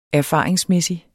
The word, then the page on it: erfaringsmæssig: -ˌmεˀsi; -ˌmεsi